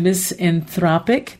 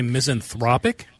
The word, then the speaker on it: misanthropic: Did you say misanthropic? – Yes. – Yep, that's the way you say that pronunciation. Yes. Cuz it's misanthrope, but if it's kind of like economy and economic, it's kind of like that.